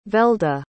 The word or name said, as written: velda